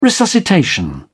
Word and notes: resuscitation: British English

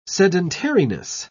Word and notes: sedentariness: British English

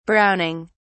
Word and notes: browning: British English

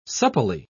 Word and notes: supplely: British English

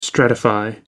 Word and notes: stratify: American English

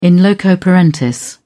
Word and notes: in loco parentis: British English

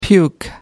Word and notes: puke: American English

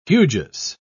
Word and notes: hugeous: British English

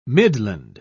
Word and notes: midland: British English

